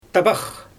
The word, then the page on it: tabax: Xyzyl